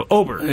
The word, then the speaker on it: Åberg: And immediately when he said it, I've been saying his name wrong for years. So, Åberg.